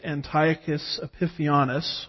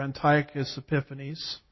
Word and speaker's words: Antiochus Epiphanes: And over time, about 171 B.C., there arose a man named Antiochus Epiphanes, or Antiochus Epiphanes. It just depends on which syllable gets the emphasis, as I like to say.